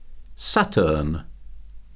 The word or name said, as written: Saturn